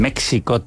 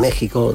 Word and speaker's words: México: Puedes elegir la J o la X al escribir. Pero al pronunciar no hay nada que elegir, es sonido J. Siempre. – Siempre. Vale. – En México, en Texas.